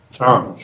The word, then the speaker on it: Sean: Sean, S-E-A-N. – Ah, okay, Sean, okay. – Sean. Sí, se pronuncia como si tuviera un H, Sean.